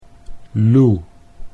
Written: Loe

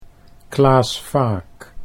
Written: Klaas Vaak

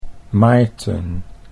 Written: Maarten

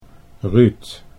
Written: Ruud

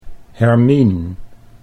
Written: Hermien

